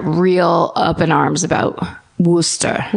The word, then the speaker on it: Worcester: Also, turns out Worcester is nuts.